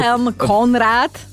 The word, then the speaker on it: Wilhelm: Skúsiš Wilhelm... – Wilhelm Konrad. – Rentgen. – Rentgen. – William Konrad Rentgen. – Ja, ja skúšam. Wilhelm. ja tam vidím Wilhelm no, v občianskom.